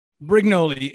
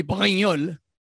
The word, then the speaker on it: Brignole: Brignole. It's a French name. It would be Brignole.